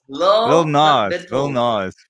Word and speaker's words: Lil Nas: Lil Nas. – Yes. – Sorry, I pronounce it like the country stars, not the hip hop stars.